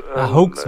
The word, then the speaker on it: Hoax: Hoax wird es ausgesprochen.